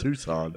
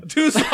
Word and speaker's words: Tucson: He said Tuscan, Arizona or some shit. – Okay. – Tuscan? That's not how you pronounce it, is it? – Tucson. – Tucson, there you go.